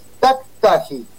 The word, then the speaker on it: Tactagi: El apellido es Taktaji.